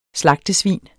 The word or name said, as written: slagtesvin